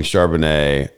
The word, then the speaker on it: Charbonnet: Well, I mean, everyone seems to be saying Charbonnet.